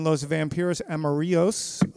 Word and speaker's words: Los Vampiros Amarillos: and he plays in Los Vampiros Amarillos. Again, am I saying that right, Kevin? Pretty good? – You're very good, sir.